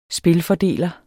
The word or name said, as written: spilfordeler